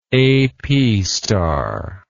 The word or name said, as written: apstar